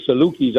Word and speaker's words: Salukis: Salukis.